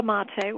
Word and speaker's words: mate: So let's talk about this mata. I'm not even pronouncing it right. – Mate, mate. – Mate. Yes, Yes.